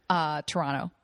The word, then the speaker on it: Toronto: Toronto. – Right. Nice not pronouncing the second T.